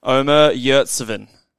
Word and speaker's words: Omer Yurtseven: And that is, I'm going to butcher this name, Callum, but that is Omer Yurtseven. Did I get that all right? Did I do all right there? – I think that was good.